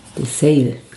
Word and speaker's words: Desailly: Dessely. – Wie schreibt man das? – D-E-S-A-I-L-L-Y. Mhm. – Dessail.